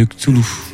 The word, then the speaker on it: Cthulhu: Cthulhu. Bon résumé. – Très très bien. – Avec parfait. Quelques erreurs. – Non. Très bonne prononciation de Cthulhu.